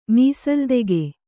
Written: ಮೀಸಲ್ದೆಗೆ

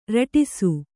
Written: ರಟಿಸು

